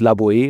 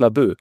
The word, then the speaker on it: Laboe: Das heißt halt nicht Laboe, sondern es heißt Labö.